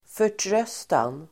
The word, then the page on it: förtröstan: för_tr'ös:tan